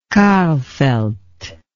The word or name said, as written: karlfeldt